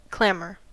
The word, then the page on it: clamor: American English